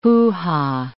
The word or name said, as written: hoo-ha